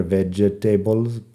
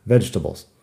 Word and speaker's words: vegetables: Y la palabra vegetables tiene una sílaba menos de lo que parece. Vegetables parece que debería ser vegetables, pero no. Vegetables.